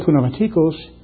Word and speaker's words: pneumatikos: And the word spiritual is the Greek word pneumatikos. In Greek you pronounce the P, in English we don't.